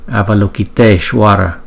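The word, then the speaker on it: Avalokiteśvara: Chữ Y có dấu gạch ngang hay là chữ Y không có dấu gạch ngang đó, trong chữ Ishwaraya khi mà ghép lại thì thành chữ E, cho nên chúng ta đọc là Avalokiteshvara.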